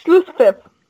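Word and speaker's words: Schlusspfiff: It's Schlusspip. – Schlusspip, that's not what Angie wrote. And you have to clearly wrote Schlusspip. – You have to make sure you make the right PF sound. It's like apple.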